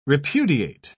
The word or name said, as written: repudiate